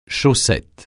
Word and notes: chaussette: francais

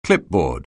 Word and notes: clipboard: anglais

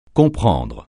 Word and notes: comprendre: francais

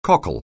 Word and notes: cockle: anglais

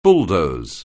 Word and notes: bulldoze: anglais

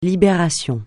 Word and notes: Libération: francais

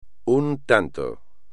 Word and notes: un tanto: espagnol

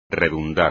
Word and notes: redundar: espagnol

